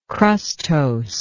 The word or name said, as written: crustose